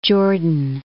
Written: Jordan

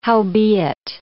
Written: howbeit

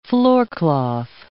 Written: floorcloth

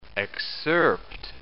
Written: excerpt

